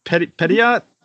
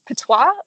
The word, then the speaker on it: Patois: So my understanding from my horrible AP French that I took for years is that it's patois, that is what I have been told, and okay, based on what I understand from French, but I'm sure somebody in France is like cringing.